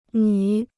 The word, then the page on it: 女: nǚ